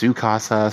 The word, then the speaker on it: Tsukasa: I know I call him Totsuka, but Tsukasa, people told me his name was pronounced Tsukasa, so Tsukasa it is.